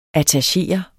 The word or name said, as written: attachere